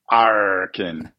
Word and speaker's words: arkin: A lot of people ask, how do you pronounce it? just say it like a pirate would, arkin, and you'll be in a good shape.